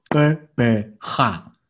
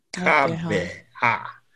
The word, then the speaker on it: Gqeberha: It's now Gqeberha. – Gqeberha.